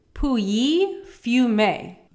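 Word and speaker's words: Pouilly-Fumé: And we are drinking Puyi Fumé. – Yeah, that's how you say it. We looked it up like 5 times. – Yep, Puyi. Kind of sounds like poo.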